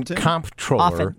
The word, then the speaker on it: comptroller: Just like in comptroller, the P is silent. Controller.